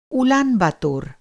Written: उलानबाटार

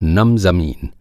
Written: नम

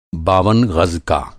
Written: बावन

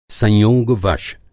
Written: संयोग-वश

